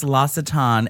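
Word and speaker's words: L'Occitane: It's called El Ocitan. Ocitan. – It's pronounced L'Ocitan, and it's from Paris.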